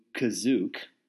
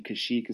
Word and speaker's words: Kashyyyk: So the plot is Chewbacca is returning to Kazook because for some reason Kashyyyk is called Kazook in this movie. – It was still spelled Kashyyyk, though. – Still spelled Kashyyyk. – But pronounced Kazook. Yeah.